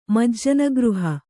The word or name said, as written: ಮಜ್ಜನಗೃಹ